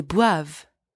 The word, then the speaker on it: boivent: Ils boivent. They drink, masculine. Ils boivent. Elles boivent. They drink, feminine. Elles boivent. And don't pronounce the ENT. At the end of a conjugated verb, ENT is silent.